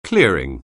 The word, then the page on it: clearing: anglais